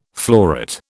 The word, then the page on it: floret: British English